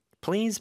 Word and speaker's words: please: PL인데 L 발음이 더 강하게 나야 돼요. 그래서 please, Z 발음도 주의하면서 please 이렇게 길게 모음 소리가 나야 되겠죠. 그 앞에 살짝 P를 넣으면 돼요. Please, please.